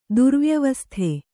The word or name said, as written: ದುರ್ವ್ಯವಸ್ಥೆ